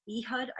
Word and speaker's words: Ehud: Ehud, I believe it's pronounced, E-H-U-D.